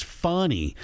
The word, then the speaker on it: Fani: Now, I know the correct pronunciation is funny, but I purposely go out of my way to call her Fannie Willis for two reasons.